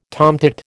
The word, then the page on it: tomtit: American English